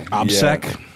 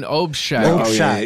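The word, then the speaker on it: obshchak: Yeah, obsec. – Yeah. No, it's like an obshack. – It's obshack, which is like umbrella organization for moving money in a criminal way.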